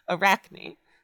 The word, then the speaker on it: Arachne: I'm assuming it's pronounced Arachne, because spiders. – I mean, that's what I would think. – And it's spelled like that. It's spelled like Daphne, but like a spider. Arachne.